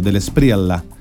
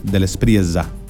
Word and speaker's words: de la Espriella: Yo recién lo pronuncié como si fuera italiano, de la Espriella, y es de la Espriella. La doble L es difícil de pronunciar, así que cada uno la pronuncia como pueda, pero me parece que viene de origen español.